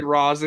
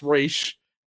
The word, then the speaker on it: Ra's al Ghul: But, you know, also they mispronounce Ra's al Ghul like repeatedly to an annoying degree. – They go Ra's. – Yes, yes, yes. They say Ra's. It's Ra's.